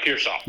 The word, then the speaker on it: Pearsall: is it Pearsall? Is that how you say it? – Yeah, Pearsall.